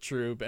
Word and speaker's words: trub: we were just saying Trub. Troob. Because that's what it looks like. Troob. But every single person I've ever worked with pronounces it Troob.